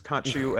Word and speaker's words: Khonshu: It's Khonshu and Ammit.